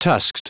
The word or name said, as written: tusked